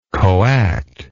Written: coact

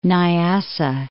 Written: Lake Nyasa

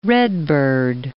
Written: redbird

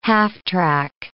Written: half-track

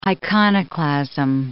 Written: iconoclasm

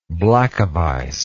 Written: blackavised

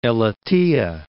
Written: Elatea

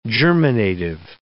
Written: germinative

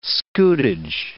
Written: scutage

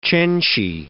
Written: Cenci

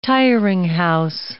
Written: tiring-house

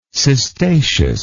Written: cistaceous

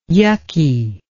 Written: yukky

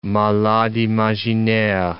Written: malade imaginaire

